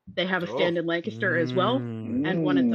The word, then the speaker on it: Lancaster: But it is pronounced Lancaster, and I will fight you about that.